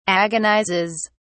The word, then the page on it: agonizes: British English